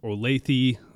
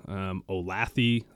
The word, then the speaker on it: Olathe: So I'm from Olathe, Kansas. Not Olath, Olathe, Olathe.